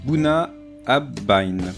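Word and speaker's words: Bunnahabhain: qu'est-ce qu'on va boire aujourd'hui Alors, attention pour euh, la prononciation. On va boire donc du whisky, ça vous étonnera. Et du Buna by... Bye bye Non, Buna Bai.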